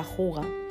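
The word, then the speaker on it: hygge: Con, su filosofía, se escribe Hige, Hige, H-Y-G-G-E, o que se pronuncia Juga,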